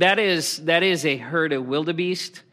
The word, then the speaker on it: wildebeest: Okay, the word wildebeest is actually an Afrikaans word, and I pronounce it like an American now, which kind of every time I say it, it bothers me. The word is wildebeest.